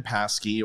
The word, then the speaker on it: Psaki: Jen Psaki or Psaki or Psaki or whatever the hell her name is. The P is silent.